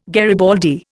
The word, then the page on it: garibaldi: Indian